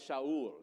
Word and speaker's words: Shaul: His son was, and now it's not Saul, it's Shaul. Everybody say Shaul. Shaul was the most handsome man in Israel, head and shoulders taller than anyone else in the land.